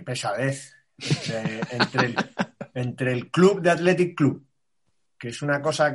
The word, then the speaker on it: Athletic: En Bilbao se dice Athletic. Pronunciando más o, más o menos fuerte la C, pero no dicen el Athletic Club, hablando de ellos mismos.